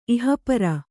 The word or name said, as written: ಇಹಪರ